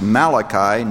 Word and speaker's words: Malachi: Find the place where the Old and the New Testament meet, and it is pronounced Malachi, not Malachi, like an Italian friend of mine thought it was.